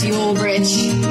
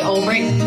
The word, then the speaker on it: Albrecht: Looks like it should be pronounced Ulbricht. But we say Albrecht, though there's no K in sight. We were German, we would say it right.